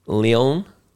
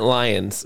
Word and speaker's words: Lyon: We mentioned a place in France many times, Lyon. – Lyon, yeah. – Yeah. – Well, I've been calling it Lions because it's spelled L-Y-O-N.